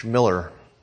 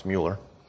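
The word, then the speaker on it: Müller: For George Miller, sometimes pronounced Mueller, and Charles Spurgeon in the 19th century, that meant opening orphanages to meet the need of the day.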